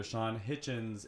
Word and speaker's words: Shawn Hitchins: We have comedian and author Shawn Hitchins in the house.